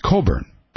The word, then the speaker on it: Cockburn: And it's co-edited by Alexander Coburn, C-O-C-K-B-U-R-N, but pronounced Coburn, thankfully, for Alex.